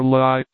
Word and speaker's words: Lai: The legend, lore, two syllables, was a water nymph who used to sit on a high rock called the Lee, or Lai, pronounced like our word lie, in the Rhine, and lure boatmen to destruction in a furious rapid which marred the channel at that spot.